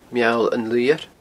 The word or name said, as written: Meall an Luidheir